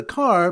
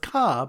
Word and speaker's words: car: So the Simmer Daf Chafalv is a car pronounced ka by people from Boston.